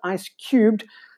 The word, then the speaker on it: ICE3X: Continuing with big news for South African cryptocurrency traders, popular altcoin exchange Ice Cubed, known commonly as ICE3X but pronounced officially as Ice Cubed,